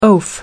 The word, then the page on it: oaf: American English